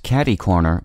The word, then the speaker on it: catercorner: The café was catty-corner to the salon, so after I got my haircut, I walked across the intersection to get some lunch. Our word today is pronounced catty-corner, but spelled as one word, C-A-T-E-R-C-O-R-N-E-R.